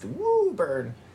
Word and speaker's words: Woburn: It's spelled Woburn, but it's pronounced Woburn.